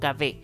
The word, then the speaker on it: kahve: Se escribe kahwa, pero en el fondo se pronuncia como kahve. Y habría llegado desde Turquía hacia Italia, donde se habría en el fondo referido como café.